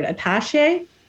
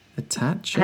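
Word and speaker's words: attaché: One of the men held a black, how do you say that word? Attache? – Attache? – Attache? Attache case? – Attache, yeah, I'd say that.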